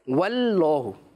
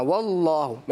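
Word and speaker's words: वल्लाहु (والله): कुछ बच्चे गलती से पढ़ लेते हैं, कैसे पढ़ लेते हैं, ऐसे वल्लाहु, वल्लाहु, पहले वाले को बारीक कर दिया ना, वल वल, और दूसरे वाले को मोटा कर दिया अल्लाहु, ये गलत, बल्कि दोनों को मोटा प्रोनाउंस करना, वल्लाहु,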